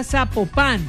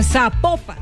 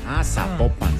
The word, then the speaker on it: Zapopan: Oiga, nos escriben, escriben los oyentes y que sapo no se pronuncia Zapopan, sino que se pronuncia Zapopan. – Zapopan.